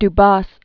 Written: Dubos

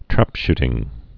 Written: trapshooting

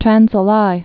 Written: Trans Alai

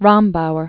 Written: Irma von Starkloff Rombauer

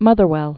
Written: Motherwell

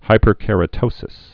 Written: hyperkeratosis

